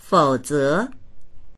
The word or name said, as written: fou3ze2